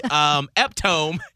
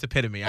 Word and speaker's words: epitome: Yeah, and so even now, still, if I'm reading something, I will always, the first second, I'll see eptome, and then I'll be like, oh, no, it's epitome.